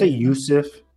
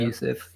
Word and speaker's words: Youssef: is I don't know how you pronounce his last name, Rami Youssef. – I think that's Youssef. – Yeah. Youssef, yeah.